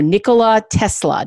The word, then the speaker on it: Nikola Tesla: Nikola Tesla.